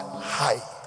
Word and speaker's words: chai: And 18, the Hebrew word that represents 18 is life, and they write the life transliteration C-H-A-I. When you see it, you may think it's the Nigerian word chai, but they pronounce it hi. 18 is a Hebrew word hi, life.